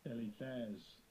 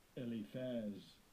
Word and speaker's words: Eliphaz: Let's get back to Job chapter 42, verse 7, where the Almighty Yahuwah is talking to Eliphaz, and let me hear that word pronounced in the Hebrew language. – Strong's age 464. Eliphaz. Eliphaz.